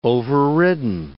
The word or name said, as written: overridden